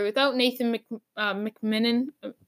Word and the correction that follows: MacKinnon: Why can't I pronounce his name? Nathan Mac MacKinnon. I always say McMinnon. Don't even ask. Like it's just one of those things. I always say McMinnon's MacKinnon. It sounds exactly the same, but he that guy's really good.